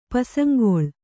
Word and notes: ಪಸಂಗೊಳ್: kannada

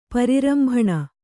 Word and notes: ಪರಿರಂಭಣ: pari rambhaṇa; Kannada

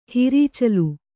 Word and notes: ಹಿರೀಚಲು: hirīcalu; Kannada

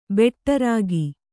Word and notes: ಭಟ್ಟಾರಕ: bhaṭṭāraka

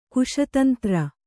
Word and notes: ಕುಶತಂತ್ರ: Kannada

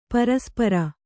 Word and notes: ಪರಸ್ಪರ: paraspara

